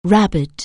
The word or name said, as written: rabble